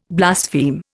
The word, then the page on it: blaspheme: Indian